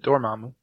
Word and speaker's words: Dormammu: 3, Dormammu. I can never say his name right, but Dormammu.